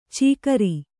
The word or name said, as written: ಚಿಕರಿ